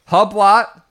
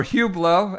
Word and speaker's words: Hublot: He's got Hublot or Hublot, as we came to understand his pronunciation, Grindworks, and all these other brands.